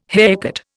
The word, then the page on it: haircut: Indian